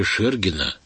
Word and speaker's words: Шергина: миниатюрой Бориса Викторовича Шергина, именно так произносится его имя,